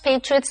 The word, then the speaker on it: patriot: OK, 继续我们看的是 Patriot 这个名词。Patriot 是指爱国者或爱国主义者。所以这里字尾的 O 是发的音，念成 Patriot。例如 Henry has been a fierce patriot since he was a teenager.